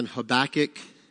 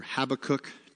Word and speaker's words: Habakkuk: Verses 2 and 3, and then Habakkuk, or Habakkuk, depending on your pronunciation.